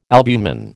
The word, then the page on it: albumin: American English